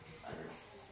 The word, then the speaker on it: Cyrus: It rhymes with Tyrus. – Cyrus.